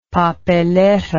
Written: papelera

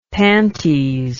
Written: panties

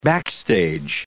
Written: backstage

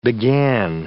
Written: began

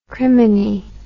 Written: criminy

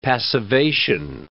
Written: passivation

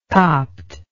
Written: copt

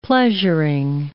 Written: pleasuring

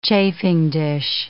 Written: chafing dish

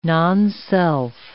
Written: nonself

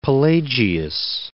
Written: Pelagius

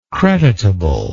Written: creditably

